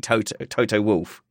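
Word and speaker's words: Toto Wolff: Literally no controversy about this. It is definitely Toto Wolf.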